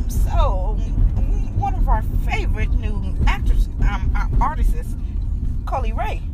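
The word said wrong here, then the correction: Coi Leray: Did I say her name right? Coley LeRae? – Coy Lee Ray? – Okay, thank you, thank you.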